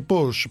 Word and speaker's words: Pausch: Il suo nome è Randy Pausch, ma si scrive P-A-U-S-C-H, cercate l'ultima lezione del professor Porsche.